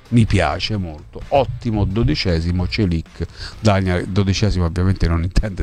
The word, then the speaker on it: Çelik: Qualcuno dice, si dice Çelik. – Sì, bravo, esatto.